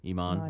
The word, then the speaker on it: Iman: Iman.